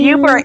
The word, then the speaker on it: Yooper: Youper, it's spelled Y-O-O-P-E-R.